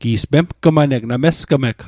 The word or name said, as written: mesg'g